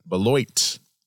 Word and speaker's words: Beloit: Is that how you pronounce Beloit. – It? Beloit.